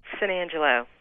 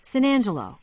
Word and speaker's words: San Angelo: So we decided to call from the Visitor Center in, how do you say it? – San Angelo. – San Angelo. – That's right. – San Angelo. – I've always just kind of rolled it together, kind of like New Orleans.